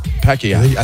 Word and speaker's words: Pacquiao: I say it the way it's supposed to be said. Right. Pacquiao. – That's how they say it. Pacquiao.